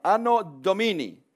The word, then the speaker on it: Anno Domini: Anno Domini.